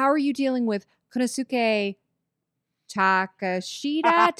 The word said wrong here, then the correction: Takeshita: tak- Takeshita.